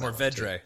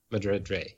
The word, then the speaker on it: Mourvèdre: I always mispronounce Mourvèdre. – So Mourvèdre.